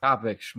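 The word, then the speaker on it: Kopech: Kopich.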